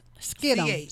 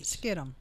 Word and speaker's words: Kittim: Shittim. Skittim. Skittim. They pronounce that H as a K. Yeah. – Skittim. – Yeah, I heard that guy say it on YouTube last night. – Okay. Mm-hmm. Skittim, which is Greece.